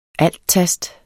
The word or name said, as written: alttast